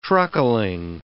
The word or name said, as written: truckling